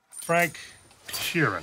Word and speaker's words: Sheeran: Frank Sheeran. Am I saying that right? – Yeah, you said it right.